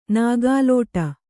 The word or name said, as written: ನೂಕಲಾಟ